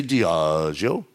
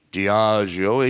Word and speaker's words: Diageo: You want to say Diageo? Diageo. He knows the guy, well, guy or girl, AI, they knew it wasn't a travel company.